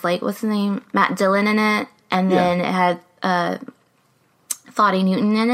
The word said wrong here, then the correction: Thandie: It's Thandie.